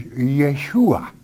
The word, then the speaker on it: Yeshua: And Joshua in the Hebrew tongue is Yeshua, okay, Yeshua. And so that's as it is pronounced.